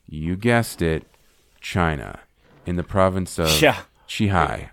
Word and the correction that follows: Qinghai: Yeah, you were close. – Qinghai. Qinghai. – I think I said Qi, but Qinghai.